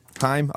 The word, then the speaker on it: Haim: I've never quite got into pronouncing them as Haim. I preferred it when it was Haim, but I really like them anyway, so I should probably say their name right, since that's their name.